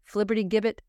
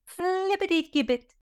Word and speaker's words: flibbertigibbet: It is spelled F-L-I-B-B-E-R-I-G-I-B-B-E-T. Flibbertigibbet. – You left out the T when you spelled it. – Oh, my. F-L-I-B-B-E-R-T-I-G-I-B-B-E-T. Flibbertigibbet. You must say it fast and with great enthusiasm.